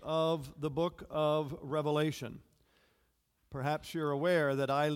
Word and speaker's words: Revelation: This morning, we are looking at God's Word found in the book of Revelation, without an S, as it's often mispronounced, the book of Revelation, chapter 2, verses 12 through 17.